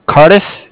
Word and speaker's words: Cardiff: From Cardex? – Cardiff. It's spelled C-A-R-D-I-F-F, as in Frank Frank.